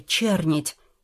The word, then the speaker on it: чернить: А что это такое, чернить? Или чернеть?» «Птица такая, чернить. Ударение на первый слог.